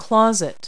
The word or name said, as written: closet